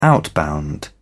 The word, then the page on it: outbound: British English